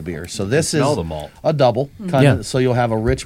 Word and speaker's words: Dubbel: It's spelled D-U-B-B-E-L. – Correct. – Double.